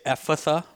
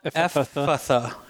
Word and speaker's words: Ephphatha: I usually hear Tyre and Sidon for the names of the towns, and then I usually say Ephphatha, but I think that's anybody's guess. – Mm. – Yeah. – So you just pronounce one of the PHs? – Well, Ephphatha, you know, it's just a longer Ephphatha.